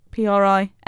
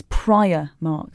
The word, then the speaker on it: Primark: What is the correct pronunciation for PRIMARK? – I think you might be right on this one. Priamark. – So you said Primark. – It's Priamark. – Oh, bugger. – It's not Primark or Primark.